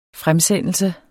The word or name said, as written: fremsendelse